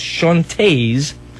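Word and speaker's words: Chaunte's: It's by a group called the Chantez, C-H-A-U-N-T-E apostrophe S, Chantez. It's got to be the right way to pronounce it.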